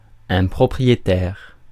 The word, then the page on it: propriétaire: France (Paris); [ɛ̃.pʁɔ.pʁi.je.tɛʁ]